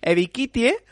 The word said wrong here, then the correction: Ebiketie: El Edge de Penn State. Es que es complicado. – Ebiketie, yo digo. – Ebiketie, vale.